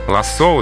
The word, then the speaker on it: лассо: Лассо, ударение в этом слове падает на последний слог, потому что слово пришло к нам из французского языка.